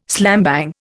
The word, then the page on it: slambang: Indian